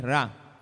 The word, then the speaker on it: Ra: Ra, R, una R y una A, pero como nosotros pronunciamos la R como doble R cuando está al principio, decimos Ra, pero es Ra.